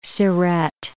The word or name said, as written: Siret